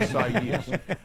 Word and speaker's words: Isaias: That is a six-letter word that has 4 syllables, by the way. Isaias.